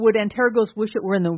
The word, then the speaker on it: Antergos: At least that's how we're pronouncing it, Antergos.